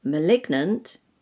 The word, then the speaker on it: malign: For example, R-E-S-I-G-N, resign, you don't pronounce the G. – Resignation, you pronounce the G. – And M-A-L-I-G-N, malign, you don't pronounce the G. – Malignant, you pronounce the G. – And finally, sign, S-I-G-N, you don't pronounce the G.